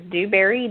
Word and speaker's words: Dewberry: My name is spelled K-I-M-B-E-R-L-Y, and then my last name is Dewberry, D-E-W-B as in boy, E-R-R-Y.